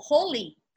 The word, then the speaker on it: Holy: No, holy.